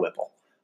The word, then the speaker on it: WPIAL: So one of them, Lamont Wade, 2 years in, five-star recruit out of the Pittsburgh area, the, I don't know how you say it, Whipple, W-P-I-A-L, it's the big, like, league out there. I call it Whipple.